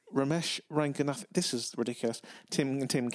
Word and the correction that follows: Romesh Ranganathan: It's, it's Roisin Conaty and Romesh Ranganathan but easy for you to say.